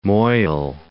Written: Moyle

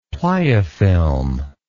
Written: pliofilm

